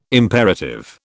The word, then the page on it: imperative: British English